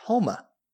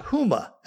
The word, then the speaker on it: Houma: The town spelled H-O-U-M-A is pronounced Houma. Not Houma, as I was saying constantly throughout episodes 1 and 2, but Houma.